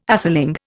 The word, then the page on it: atheling: Indian